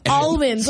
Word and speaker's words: almonds: Almonds, like you pronounce the L. – L. Oh, God. – How do you say S-A-L-M-O-N, the fish? – Salmon.